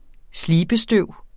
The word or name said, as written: slibestøv